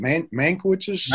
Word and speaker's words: Mankiewicz: He's the one, how do you say Joseph, is it Mankiewicz's? – Mankiewicz, uh-huh. Uh-huh.